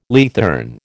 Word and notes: leathern: American English

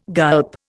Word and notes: gulp: Indian

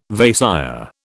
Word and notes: Vaisya: British English

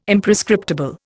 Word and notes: Imprescriptible: Indian

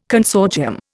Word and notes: consortium: Indian

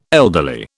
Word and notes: elderly: British English

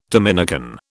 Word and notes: Dominican: British English

